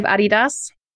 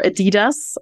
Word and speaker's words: Adidas: So we'll start with Adidas, or Adidas, as it's pronounced in American. – Let's go with the German pronunciation, because I think that's probably the right way.